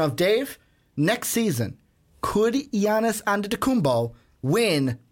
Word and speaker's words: Giannis Antetokounmpo: See, I didn't do the hard G. Yep. I didn't give you the Giannis. No, it's Giannis Antetokounmpo.